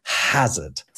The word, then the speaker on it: hazard: By the time it gets via French into English, it started with a ha that the French are presumably just ignoring anyway. It's there, but they're not pronouncing it. And then it gets into English and we go, oh, but there's an H at the start of it. So let's pronounce the H. So it becomes hazard.